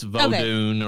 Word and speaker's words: Vodun: Well, they do it pronounce it different ways. It's Vodun or voodoo.